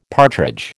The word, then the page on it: partridge: American English